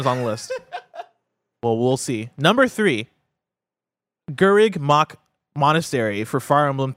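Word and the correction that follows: Garreg: Gareg is how you say it.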